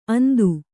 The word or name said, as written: ಅಂದು